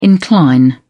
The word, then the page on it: incline: British English